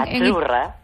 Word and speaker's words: azzurra: Azurra. – Azurra. Mira, ¿ves? – Azurra. Es que... Azurra. Sí, como porque pizza. La Z no es la Z como decimos en catalán tan sonora, no. Ah, es azurra.